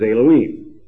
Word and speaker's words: Elohim: That is merely a transliteration into English of the Hebrew, and the pronunciation in Hebrew is Elohim, Eloah, or El being the singular.